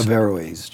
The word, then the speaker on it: Averroes: I'm still having trouble with the pronunciation because I keep saying Averroes, but it's Averroes. – Averroes. I just get the, and I need to leave out the N, Averroes.